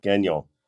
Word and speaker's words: Gagnon: Am I pronouncing that right, Warren? – Gagnon. – Gagnon. It's French.